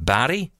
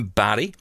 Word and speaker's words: Barrie: There's one in, where is it, Barrie? Am I getting this right? I think I'm saying this right. There's going to be one in Barrie.